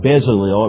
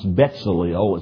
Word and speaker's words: Betzalel: His name was, it looks like Bezalel. It would actually be pronounced Betzalel. It's more like B-E-T-Z, Betzalel.